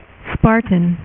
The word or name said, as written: Spartan